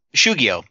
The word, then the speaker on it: shugyo: The Shogyo? Is that how we Shugyo? – Pronounce it? Shugyo.